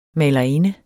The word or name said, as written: malerinde